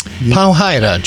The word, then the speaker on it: Powhida: How do you pronounce that? – Powhida. John Powhida. He actually made such a big deal about it that on social media he had changed his name, and in parentheses he says, it's pronounced Powhida.